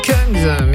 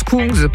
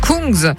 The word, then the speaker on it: Kungs: Bon, j'ai dit Kungs, mais ça se prononce Kungs, pardon, Kungs, avec This Girl pour vous souhaiter un beau dimanche à l'écoute d'Ici Lorraine, 8h39.